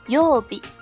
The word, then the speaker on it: 曜日: 它们相同的这个部分曜日，它的日文发音是 yobi，重复一遍哟， yobi。